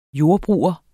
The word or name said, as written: JORDBRUGERENS